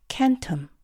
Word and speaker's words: centum: 라틴어로 헌드레드는 C-E-N-T-U-M, 캔텀. 기억하시겠지만 라틴어에서 C는 항상 K 사운드로 발음되었죠. 그리고 다른 하나는 산스크리트어의 헌드레드에 해당하는 S-A-T-E-M, 사템입니다.